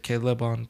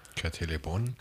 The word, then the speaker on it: Le Bon: No quiero decir Kate Le Bon porque se escribe como en francés. – Kate Le Bon.